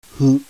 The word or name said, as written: HU